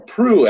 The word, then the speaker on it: Proulx: And that's Annie Proulx, or Proulx, actually, I heard is the correct pronunciation.